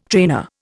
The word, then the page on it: trainer: Indian